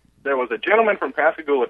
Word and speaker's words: Pascagoula: It's Pascagoula. – Pascagoula.